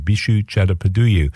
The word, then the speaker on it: Bishu Chattopadhyay: And that's the sound of New York-based Indian musician Mr. Bishu Chattopadhyay. I'll spell that for you if you like. Bishu is B-I-S-H-U and then C-H-A-T-T-O-P-A-D-H-Y-A-Y. Bishu Chattopadhyay.